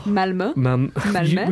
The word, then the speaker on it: Malmö: Well, Malmö. – We've been there like 5 years in a row. Would think we would get there, yeah. But we're going to Malmö. We're going to Malmö and we're going to do Marioki at the wonderful Nordic game.